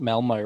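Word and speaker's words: Malmö: I hope I'm saying it right. That's the It is Malmo. They hit us, hit us up after the Festival Football podcast saying we nailed it.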